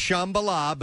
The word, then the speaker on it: Shambhala: I think it's pronounced Shambhala, but that is exactly Shambhala. Right. Mm-hmm. Yeah, so it's a piece of paper that Strange thinks is a mantra. It's actually the Wi-Fi password. And it's Shambhala or it's Shambhala. I could see you pronouncing it that way.